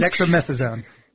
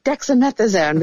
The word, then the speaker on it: dexamethasone: But to be clear, as you said, he's on an elaborate cocktail of drugs, some of which, including the steroid that does, I'm not going to pronounce it correctly, that you mentioned. – Dexamethasone. – Dexamethasone, there we go, is really reserved for pretty serious cases.